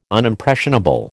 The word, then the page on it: Unimpressionable: American English